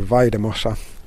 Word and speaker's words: Valldemossa: So I think I said earlier Valldemossa with a hard L sound, but that double L is pronounced with a Y, so Valldemossa.